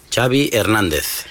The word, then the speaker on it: Xabi Hernandez: What is the correct pronunciation of your first and last name? – Xabi Hernandez. – Xabi, okay. – Yeah. – So I heard the Xabi like chocolate. – Yes, okay, thank you very much.